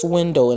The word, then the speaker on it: Swindoll: Okay, it was by a philosopher named Charles Swindoll, and it's not like swindle like the verb, is S-W-I-N-D-O-L-L. Okay, so it's like Swindoll, that's how you spell it.